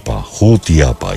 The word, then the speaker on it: Jutiapa: Vamos a orar mucho por todos los hermanos de Yutiapa. Es así como se pronuncia, ¿verdad? Yutiapa, para que... Jutiapa, ya.